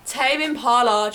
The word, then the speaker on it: Tame Impala: Dracula tame implant wear. – Tame implant. – I think, how do you say their name? – Tame Impala, Dracula.